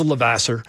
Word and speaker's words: Levasseur: one being Raymond Luc Lavoisier. Am I saying that right? – He's from Maine. He just says Levasseur.